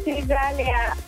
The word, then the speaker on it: Nizalia: My name is Nizalia. – Ooh, you're... Sorry, you said your name is... – Nizalia. – Nizalia. Could you spell that for me, please? – N-I-Z-A-L-I-A. – Ooh, N-I-Z-A-L-I-A.